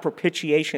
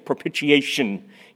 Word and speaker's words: propitiation: And he always used to say, it's not propitiation, it's propitiation.